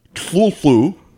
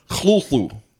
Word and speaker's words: Cthulhu: Lovecraft, of course, pronounced it, according to his letters, in two... different ways. One was Tlulu, and the other way that he pronounced it, according to a different letter, is Tlulu.